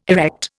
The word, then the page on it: erect: Indian